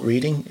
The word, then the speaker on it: Reading: Pronounced Reading but spelt Reading,